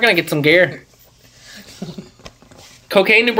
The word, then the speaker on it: Cocagne: C-O-C-A-G-N-E. Cocaine. – No way. That's good. Cocaine, New Brunswick. It's spelled like Cocagne, but it's in Kent County.